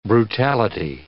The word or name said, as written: brutality